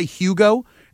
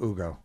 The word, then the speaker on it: Hugo: Some people say Hugo, and some people say Ugo. They, don't pronounce the H. And if you remember, that's how the commissioner actually announced it on the podium. Maybe when we talk to Scal at some point, I need to get to the basics. – I think it's the proper Spanish pronunciation of Ugo.